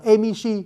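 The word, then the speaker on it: Emishi (蝦夷): Họ viết chữ Hà Di mà đọc là Emishi.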